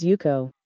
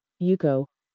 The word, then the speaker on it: Yuko: Hello, my name is Yuko. – Can you repeat that? – Yuko. – It's spelled Y-U-K-O.